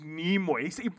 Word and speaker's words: Nimoy: He goes, you pronounced Nimoy wrong. I was saying Nimoy. – You know what? – I was saying Nimoy. He's like, he goes, it's Nimoy. He wrote it out for me, what do you call it? Phonetically 3 times. Nimoy.